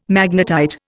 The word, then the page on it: magnetite: Indian